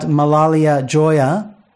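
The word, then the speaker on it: Malalai Joya: And John, you were talking there about Malalia Joya. Did I get the name right this time? – That's pretty close. Yep. That's now, pretty good.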